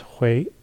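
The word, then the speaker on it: hui (回): It's pronounced hui.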